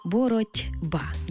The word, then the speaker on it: боротьба: Але вимовляємо і чуємо це слово зовсім інакше. Боротьба, бо дзвінкий приголосний «Б» вплинув.